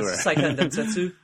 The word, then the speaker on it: Seiken Densetsu: You know how to pronounce it, I imagine. Sengetsu Densetsu. – Oh, Seiken Densetsu? – Yeah. You pronounce it better than I do.